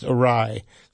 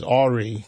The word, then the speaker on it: awry: And I like that you knew it was pronounced awry. I've heard people mispronounce it as awry because they, you know, never heard it pronounced.